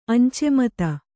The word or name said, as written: ಅಂಚೆಮತ